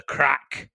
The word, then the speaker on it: craic: Obviously, what's the crack? Spelled C-R-A-I-C. That's how I spell crack now. I don't spell it C-K.